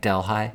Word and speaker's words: Delhi: It's in Delhi, New York. Am I saying that right? Delhi? – Delhi.